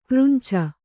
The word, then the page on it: ಕರಂಚು: karancu